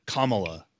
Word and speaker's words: Kamala: So it's Kamala. I didn't know how to pronounce it either.